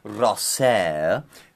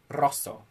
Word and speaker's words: Rosso: It's a maxi show, so that means it's in Shinjuku Gyoenmae at the aforementioned venue, Rosser, which is how I say it. But it's pronounced Rosso.